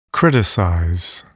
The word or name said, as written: criticize